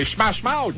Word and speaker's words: Smash Mouth: C'est Smash Mouth.